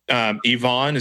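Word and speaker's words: Yvon: But you mentioned Yvonne.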